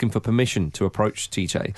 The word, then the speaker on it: Tite: Tite. Yeah. – Or Chiche, would it be pronounced? – Yeah.